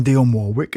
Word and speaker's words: Dionne Warwick: And she was one of those people that had a real aura, an aura of, I am Dionne Warwick, and she told me that's how I must pronounce her name.